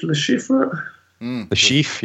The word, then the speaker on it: Le Chiffre: Le Chiffre, yeah.